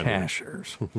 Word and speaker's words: Cashiers: No, it's Cashiers. So if you ever want to go there and not sound like a tourist, pronounce it correctly.